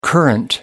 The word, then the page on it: current: American English